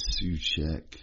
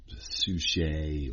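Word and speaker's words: Souček: My name, I always had to spell it out, and it was Soček and Suchet, or you name it.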